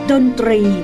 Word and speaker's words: ดนตรี: คำว่าดนตรีต้องออกเสียงตอควบรอส่วนคำว่าคลายเครียดต้องออกเสียงคอควบรอและคอควบรอให้ชัดเจนจึงจะมีความหมายว่าดนตรี